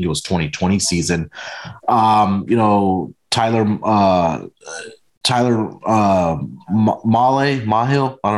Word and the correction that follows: Mahle: don't know how to say his name, Tyler Mahle.